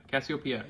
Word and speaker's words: Cassiopeia: Cassiopeia, yeah.